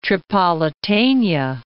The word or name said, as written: Tripolitania